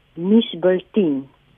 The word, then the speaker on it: nuusbulletin: Maar om terug te kom na jou vraag, um, volgens die uitspraak Woordeboek is die uitspraak misbulteen. Nou ons hoor daardie uh, klink 'n bietjie anders as wat ons hom vandag uitspreek.